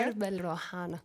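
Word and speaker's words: Charbel Rouhana: Shalbel Rouhana. Zeg ik dat goed? Ongeveer? – Shalbel Rouhana. – Kijk, zo ja.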